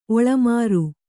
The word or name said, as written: ಒಳಮಾರು